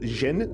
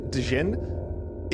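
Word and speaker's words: Dhejne: I'm pulling up Reddit because Cecilia, her last name is pronounced Jin, Dijin, explains and downplays why you're wrong about that 80-85%, because she's the one who actually did that study.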